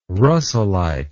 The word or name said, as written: russellite